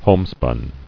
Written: Homespun

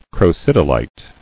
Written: Crocidolite

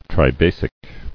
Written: Tribasic

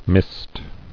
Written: Misted

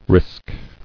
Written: Risked

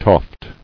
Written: Toft

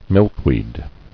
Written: Milkweed

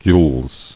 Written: Gules

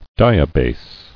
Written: Diabase

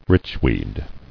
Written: Richweed

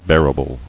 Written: Bearable

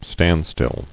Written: Standstill

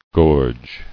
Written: Gorged

